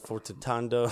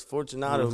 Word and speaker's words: Fortunato: They better say his name right this year. Fortunato. They're like Fortitando. – Fortitando. – Like, it's Fortunato, man.